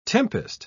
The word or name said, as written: tempest